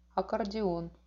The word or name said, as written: akkordion